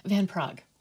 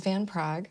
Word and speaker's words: Van Praagh: Van Praagh. – James Van Praagh.